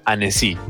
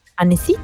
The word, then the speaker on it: Annecy: Se pronuncia Annecy. – Y yo sabía. yo te dije. – ¿Annecy? – Annecy, te lo dije, sí.